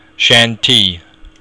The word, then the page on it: seantigh: Irish